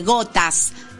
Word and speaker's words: Ghotazz: Gotas, que se llama así, Gotas. ¿Cómo se escribe el grupo? – Se escribe G-H-O-T-A-Z-Z.